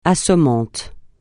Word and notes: assommant: francais

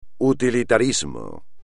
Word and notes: utilitarismo: espagnol

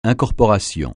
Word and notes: incorporation: francais

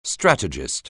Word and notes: strategist: anglais

